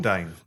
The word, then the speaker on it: Bourdain: Bourdain.